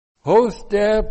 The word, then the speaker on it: hoste: Hoste plus the infinitive. H, long O, S, T, E.